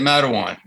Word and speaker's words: Matawan: It's a great story because you're an East Coast guy growing up in, is it Matawan, New Jersey? Is that how you pronounce it? – Yeah, Matawan.